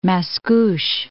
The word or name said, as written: Mascouche